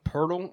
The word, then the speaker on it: Poeltl: It's actually pronounced Poeltl, and I don't know how.